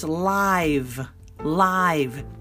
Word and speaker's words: live: Now, when it's a long I, it's live, live, and it's an adjective.